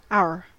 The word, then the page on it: our: American English; IPA: [aʊ.ɚ]